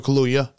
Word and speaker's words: Kaluuya: Best supporting actor, Daniel Kaluuya. Did I say that right? – Daniel Kaluuya.